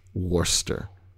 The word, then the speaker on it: Worcester: I still can't pronounce that word. Worcester. – Worcestershire. – Worcestershire. – Yeah, that's exactly it.